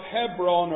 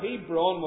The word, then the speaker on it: Hebron: Now, one of these cities went by the name of Hebron, or Hebron, whatever way you want to pronounce it, in the part of the land that was held by Judah.